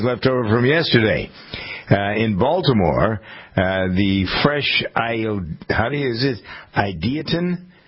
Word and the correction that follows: Ideation: food group, never heard of it. – Ideation, mm-hmm. – Oh, Ideation.